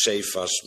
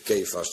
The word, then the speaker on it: Kefas: Naar het Nederlands moet je het uitspreken als Cephas, maar in het Aramees schijnt het weer Kefas te zijn.